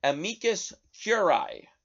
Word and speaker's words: amicus curiae: Now, the 100 House Republicans signing the legal brief, signing the amicus curiae, as it's pronounced,